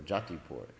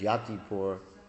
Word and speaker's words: Yatipur: Just like there's this town right next to Govardhan called Yatipur, or Jatipur. That's how they pronounce it. But it's actually, in Sanskrit, it's Yatipur, and they would pronounce it. It's a Bengalicized pronunciation of it, Jatipur, which means the city of the sannyasis, because it's Madhavendra Puri's city.